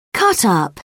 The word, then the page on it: cutup: British English